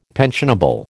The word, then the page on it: pensionable: American English